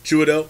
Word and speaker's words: Chiwetel: Chiwetel.